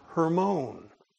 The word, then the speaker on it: Hermon: It's Mount Hermon, or as the Jews would probably pronounce it, Mount Hermon.